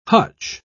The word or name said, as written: hutch